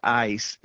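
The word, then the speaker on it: eis (εἰς): The Greek word there for the remission of sins, the Greek word is pronounced eis. It's usually anglicized. It's spelled E-I-S.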